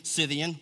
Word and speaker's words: Scythian: Here in this church, there is not Greek or Jew, circumcised and uncircumcised, barbarian, Scythian, look that up, that's how you say it, slave, free, but Christ is all and in all."